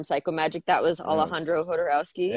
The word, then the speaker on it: Jodorowsky: Jodorowsky, if you use the Spanish pronunciation.